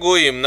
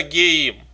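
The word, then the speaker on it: гоим (גוים): То есть пишется так, а читается по-другому. И мы читаем, когда мы читаем Тору, мы читаем гоим, народы. Но написано геим.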